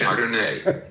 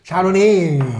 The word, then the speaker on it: Chardonnay: Keiner sagt Chardonnay. Außer du möchtest besonders kosmopolitisch klingen. Und das ist eine der Belehrungen, die mir leider relativ oft begegnet. Also eine leichte Betonung auf der 3. Silbe, alles kein Thema.